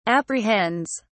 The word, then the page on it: apprehends: British English